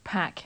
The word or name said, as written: pack